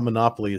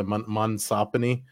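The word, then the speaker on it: monopsony: it's not monopoly, it's the other monsopony, whatever the term is for what they're doing, and this is a perfect example of it.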